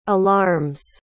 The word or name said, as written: alarms